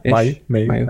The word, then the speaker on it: Mayu: Yeah, Mayu. I get everything. – Okay. All right. Well, I did my best. What do you prefer to go by? – Mayu.